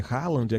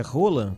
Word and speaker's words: Haaland: E eu realmente vi a comemoração do craque norueguês, né, que a gente chama de Haaland aqui no Brasil. Alguns dizem que é Roland, que precisa dizer o nome dele, é como se pronuncia na Noruega.